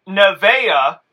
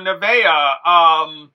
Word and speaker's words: Nevaeh: Well, it's Heaven spelt backwards. – Oh, shit. – Yeah. – Wow. – Yeah. Nevaeh, this was not that great of a match.